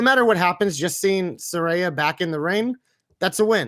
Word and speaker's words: Saraya: Or Saraya, I always say it wrong. Saraya. I don't know which one's which anyway. Saraya, that's the one actual way.